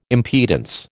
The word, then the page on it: impedance: American English